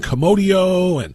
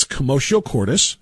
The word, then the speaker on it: commotio cordis: Because almost everybody who's talking about it is mispronouncing it. They're saying commodio and all kinds of things. It's commotio cordis, and most people have no idea what it is.